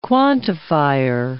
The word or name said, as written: quantifier